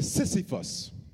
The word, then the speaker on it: Sisyphus: The plight of the Trix rabbit has drawn comparisons to Sisyphus, a Greek figure. I'll spell it for my note takers. S-I-S-Y-P-H-U-S.